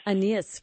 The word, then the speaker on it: Aeneas: And I don't remember how you say his name, Aeneas? – Aeneas. Right? I like the Scots word for yes, Aeneas.